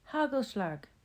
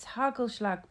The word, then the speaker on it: Hagelslag: I mispronounced the name Hakelschlakel when she presented them to me, and her son gleefully corrected me. Hagelslag! I said it incorrectly again, and he corrected me again with gusto. It's Hagelslag!